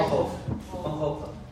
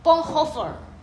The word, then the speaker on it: Bonhoeffer: Bonhoeffer. – Bonhoeffer. – Okay. – Yeah. Bonhoeffer.